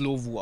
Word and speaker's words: Lovo: Lavu? Nee, Lavu, ne? Lo Ich würde es Lovu aussprechen. L-O-V-O.